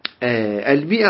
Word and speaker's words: مئة: فقديما كانوا يكتبون المئة هكذا يعني منه مثل شلون منه ونكتب منه فكانت مشكلة فقالوا احنا يعني حتى نميزها المئة عن منه نجعل هذا الألف فتكتب ولا تنطق تكتب ولا تنطق